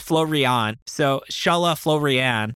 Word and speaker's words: Csala: I like how he explained it through anime terms, where he said, so C-S-A-L-A is pronounced the same as the famous Dragon Ball opening theme, Shala, as in head Shala. And then he said his last name, F-O-O-R-I-A-N, with a lot of, whatchamacallits? – Umlauts and stuff, yeah.